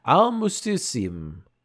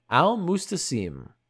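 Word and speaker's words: Al-Musta'sim: But this is Al-Mustasim. Al-Mustasim. There we go. I said it right that time.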